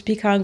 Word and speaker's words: Pecan: Well, how do you say it? Pecan or Pecan? – Like Pecan. The nut.